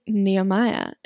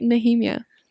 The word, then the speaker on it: Nehemiah: And she's like, Nehemiah. And then she showed it to me and I was like, oh, that's how you say it? I've been saying Nehemiah.